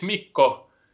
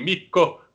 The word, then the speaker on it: Mikko: And since my name is Mikko, and classic is in Finnish klassikko, and it rhymes, so I always get to say the match will be Mikko klassikko.